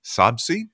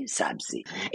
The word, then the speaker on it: sabzi: I'm going to go try to find a good recipe for gourmet sabzi. Is that how you say it? Yes. Gourmet sabzi.